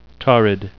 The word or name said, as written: torrid